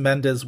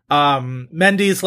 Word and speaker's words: Mendes: His father is Trinidadian, so I guess Mendes would make sense. I've just heard it pronounced 8 billion different ways since American Mendes. – Beauty times. Mendy's, like, the restaurant that Jerry Seinfeld promises he'll take that annoying comedian to, that he promises a dinner, and you said you'd take me to Mendy's.